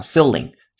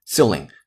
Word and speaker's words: ceiling: ceiling 天花板，注意这个单词的发音，中间的 e i 字母组合不读成 a，而读成如果是英音就是长音的 e，如果是美音就是短音的 e。那长音短音其实没有那么大的所谓啊。 ceiling ceiling 天花板 c e i l i n g，然后 spaces 啊空间，这很简单。